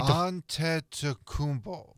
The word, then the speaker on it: Antetokounmpo: Well, first of all, you go Giannis. Antetokounmpo.